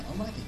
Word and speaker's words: Omake: Omake. – Yeah. Omake, that's right.